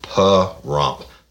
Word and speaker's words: Pahrump: Uh, Alyssa Frith Reeves Alyssa Reese from Baton Rouge, Louisiana, currently living in Par Hump, Puh Hump, Puh Rump. it's spelled P A H R U M P, and she put it in parentheses. It's P U H dash Rump, Puh Rump, Nevada.